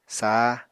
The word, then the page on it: sah: apatani